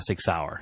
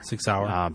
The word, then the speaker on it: Sig Sauer: I think they do a thing with, is it Sig Sauer? Is that how you say it? – Sig Sauer. – Sig Sauer.